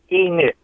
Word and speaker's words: Enoch: It's pronounced Enix. Like E in front of Nick.